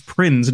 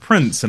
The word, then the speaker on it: Prinze: We've got Freddie Prinze Jr., and I only discovered today it's Prinze, not Prince, and I'm upset. – Oh, is it? I thought it was Prince. – No, it's spelt with a Z.